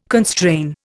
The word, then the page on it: constrain: Indian